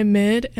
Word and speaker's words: MYD: But the one after that one is Song For You by MID, M-Y-D, is how it's spelled.